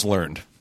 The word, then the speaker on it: learned: And please, it's pronounced learned. You idiot.